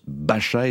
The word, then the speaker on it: Basa: Alors, ça s'écrit Bassa, B-A-S-A, mais ça se prononce Bacha et ça signifie, en langue basque, sauvage.